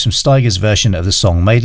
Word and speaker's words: Stigers: performed here by Curtis Stigers, rhymes with tigers, in brackets, what's so funny about, close brackets, peace, love and understanding.